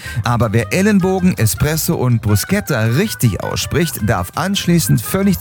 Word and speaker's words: Bruschetta: Bruschetta heißt Bruschetta.